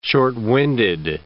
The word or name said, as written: short-winded